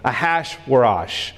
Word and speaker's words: Ahasuerus: Now, in the days of Ahasuerus, that's how you pronounce that name, Ahasuerus.